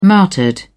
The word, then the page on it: martyred: British English